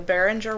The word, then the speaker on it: Beringer: So I decided to do the most haunted one in Napa Valley, which is, I really hope this is right, the Beringer? – That's how I would pronounce it. – Okay, the Beringer Winery.